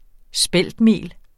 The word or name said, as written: speltmel